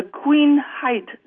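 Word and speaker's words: Queenhithe: Actually, it's called the Queen Height Dock Mosaic. And height is spelled H-I-T-H-E.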